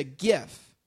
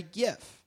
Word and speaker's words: GIF: The soft G, they say, you look at words like gin and jibe, which are both spelled G-I. So when it's a G followed by a vowel, it's supposed to be a soft G, as in gin, which would make it GIF.